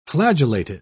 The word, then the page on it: flagellated: British English